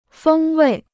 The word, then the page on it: 风味: fēng wèi